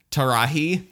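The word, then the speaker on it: Taraji: It's L-A-R-A-J-I. Henson, Larahi. – Oh, I think that's, I think it's a T. – Oh, it is. It is a T. It is a T. Tarahi Henson.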